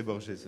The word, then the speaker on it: Borges: Par rapport à Borges, je veux prononcer Borges aussi, comme ça, d'accord, on est ça, d'accord.